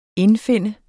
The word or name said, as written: indfinde